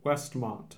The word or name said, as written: Westmont